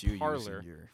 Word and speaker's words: Parler: Well, it is, but parlay, P-R-L-E-R, is to talk in French. – Okay. – It's parlay, but it's pronounced parlor. The app is pronounced parlor.